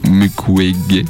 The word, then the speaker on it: Mukwege: Vous savez, avec ce fameux docteur qui soigne les femmes violées au Congo, le docteur Mukwege.